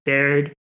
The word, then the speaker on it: Baird: Like Lisa, you know, Baird, I don't know if I pronounced her last name correctly. – Yep, that's right.